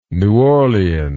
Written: new orleans

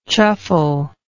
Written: chuffle

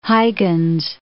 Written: Huygens

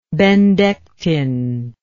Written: Bendectin

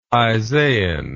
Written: isaian

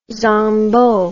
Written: zangbo

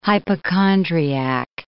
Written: hypochondriac

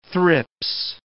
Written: thrips

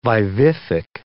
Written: vivific

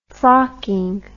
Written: frocking

